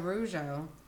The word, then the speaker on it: Rougeau: Oh, Rougeau.